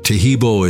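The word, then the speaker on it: Taheebo: Tehibo is spelled T-A-H-E-E-B, like boy, O, then the word T, and then the word club.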